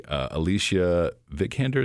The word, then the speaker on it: Vikander: And I got to admit, the lady, Alicia Vikander, is that how you pronounce her name? – Yes.